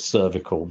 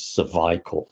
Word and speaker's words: cervical: I'll call it cervical because I prefer that to the English pronunciation, which is cervical.